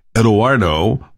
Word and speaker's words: Eduardo: And it is the Italian, not the Hispanic pronunciation of Eduardo. So it's Eduardo, all right.